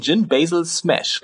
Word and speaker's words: Gin Basil Smash: Ich hoffe, ich spreche ihn richtig aus, den Gin Basil Smash, richtig? Das hast du sehr richtig ausgesprochen, das stimmt.